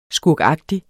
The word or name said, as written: skurkagtig